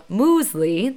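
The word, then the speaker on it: muesli: I had something called Moosley, that's actually the pronunciation of it, a few months ago on a women's retreat.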